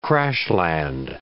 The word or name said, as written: crash-land